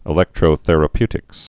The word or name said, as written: electrotherapeutics